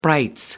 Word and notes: brights: British English

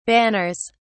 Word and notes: banners: British English

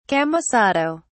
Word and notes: camisado: British English

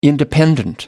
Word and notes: independent: American English

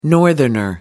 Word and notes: northerner: American English

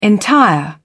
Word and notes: entire: British English

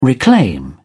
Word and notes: reclaim: British English

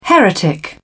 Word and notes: heretic: British English

